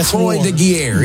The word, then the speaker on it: Croix de Guerre: The Croix de Guerre.